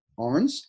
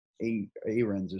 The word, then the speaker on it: Arns: And then the 3-point shooter, I never know how to pronounce his name, Justin Arns, I believe it is. I always say like Arens or something. Arns, Justin Arns.